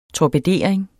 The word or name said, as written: TORPEDERING